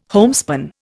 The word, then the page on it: homespun: Indian